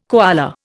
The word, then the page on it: koala: Indian